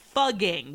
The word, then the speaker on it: Fugging: Fucking will now be known, starting in 2021, as fugging with two G's. So F-U-G-G-I-N-G, which is pronounced almost the same, but not a swear word in any language.